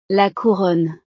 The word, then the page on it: la couronne: frances